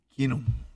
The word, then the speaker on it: Keenum: Keenum. – Keenum. ¿Por qué la L hace sí? – La doble E. – La doble E se pronuncia como I.